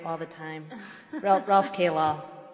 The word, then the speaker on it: Kalal: State Ex Rel Kalal, K-A-L-A-L, if I've mispronounced that. That's. – I think it's Kalal.